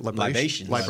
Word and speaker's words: libations: librations. Did I say that right? – Libations.